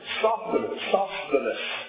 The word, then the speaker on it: Sosthenes: By the way, we find out in 1 Corinthians chapter 1 that Sosthenes, I didn't say his name, Sosthenes, there it is, that the Lord saved him as well.